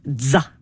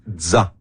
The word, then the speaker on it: ZZA: On l'appelle ZA, comme la fin de pizza. – ZA.